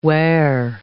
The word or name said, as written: weir